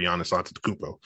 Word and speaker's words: Giannis Antetokounmpo: and the Bucks don't have their high-paid superstar Giannis Antetokounmpo. – Ooh, good job saying that name.